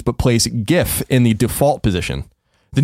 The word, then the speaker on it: GIF: The creators of the format pronounce GIF as GIF, with a short G as in gin.